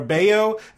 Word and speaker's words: Bello: And then it's it's B-R-A-Y-A-N for Brian. It's B-R-A-Y-A-N. It's Bello or Bayo. It's B-E-L-L-O.